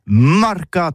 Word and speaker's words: marcat: Eh bien, d'ici à toutis, le mercat de l'Uni ou le mercat des rebelles, mais en gascogne toulousain, c'est prononcé les marcat, avec un A marquette.